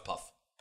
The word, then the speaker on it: Puth: Oh, if he's German, then it's pronounced Put. – No, it's Puff. – Puff. Put. – It's Puff. – T-H is Tuh. – I don't care, it's Puff. – But it's Tuh, puff. Like Goethe.